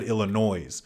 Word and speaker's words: Illinois: And I just said Illinois, and I hate that because it's Illinois, and you don't pronounce the S.